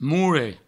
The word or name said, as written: moure